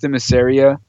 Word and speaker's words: Themyscira: And I always say it's Themyscira, but I know I'm putting too many letters in there.